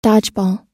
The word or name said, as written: dodgeball